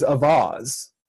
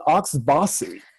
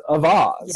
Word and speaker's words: Auxvasse: I think the only one that's actually correct, at least in the sphere of mid-Missouri, is Avaz. It's spelled A-U-X-V-A-S-S-E, and I've heard people call it Oxbossy, but it's actually is one of the few places that's pronounced correctly in French as Avaz.